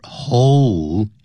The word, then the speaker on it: hole: You should pay very close attention to the pronunciation of this word, hole, H-O-L-E.Because this word involves this letter L, so that's why as we read this word, we should say hole.